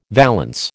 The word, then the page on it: valance: American English